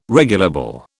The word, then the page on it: regulable: British English